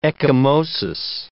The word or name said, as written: ecchymosis